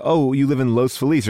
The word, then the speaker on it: Los Feliz: It's the reason why people in Los Feliz call it Los Feliz, but if you were to correctly pronounce it, it would be Los Feliz.